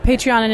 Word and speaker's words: Patreon: It's Patreon? – Patreon, and it's a crowdsourcing platform.